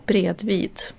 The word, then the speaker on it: bredvid: Ja, jag är alltså 45 år nu och har fått höra att det heter inte bröve. Du kan sätta dig bröve mig. Det heter bredvid.